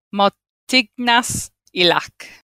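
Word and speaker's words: Martignas-Ilac: Okay, so it's spelt M-A-R-T-I-G-N-A-S. Then it's got a dash. Then it's an I-double-L-A-C. Martignas Ilac.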